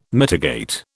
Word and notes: mitigate: British English

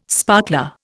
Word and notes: sparkler: Indian

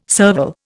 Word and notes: serval: Indian

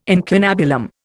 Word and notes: incunabulum: Indian

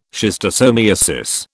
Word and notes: schistosomiasis: British English

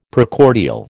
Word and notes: Precordial: American English

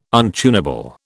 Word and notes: untunable: British English

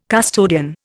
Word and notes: custodian: Indian

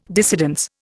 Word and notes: dissidence: Indian

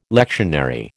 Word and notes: lectionary: American English

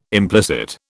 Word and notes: implicit: British English

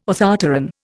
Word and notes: orthopteran: Indian

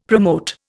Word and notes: promote: Indian

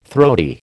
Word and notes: throaty: American English

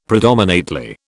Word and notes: predominately: British English

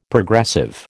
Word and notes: Progressive: American English

